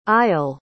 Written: eyal

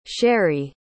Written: shari

